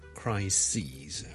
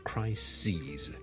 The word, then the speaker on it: crises: 上一集講嘅最常見嘅例子就 crisis 啦，c-r-i-s-i-s 一個危機啦，a big crisis 啦，但係 number of crises， OK，留意個發音啊，串字當然係唔同啦，個發音係唔同嘅，crisis 同 crises。